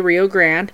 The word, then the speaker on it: Rio Grande: So they'd go to the mighty Rio Grande River, or as locals say, Rio Grande, that ran through town, and they would retrieve these huge chunks of ice and bring them back to the ice house.